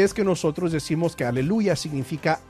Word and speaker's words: aleluya: Este último elemento de aquí, estas últimas dos letras, es la abreviación, se pronuncia ya, por eso es aleluya.